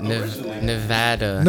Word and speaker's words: Nevada: Nevada. – Nevada? My bad. – It's like everybody from like the U.S. says it like that, except people from Nevada. – Yeah. – Like everybody says it. – Nevada.